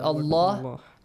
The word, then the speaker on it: الله: The lam in the word Allah, which is a heavy letter here. It's not a heavy, it's pronounced heavily.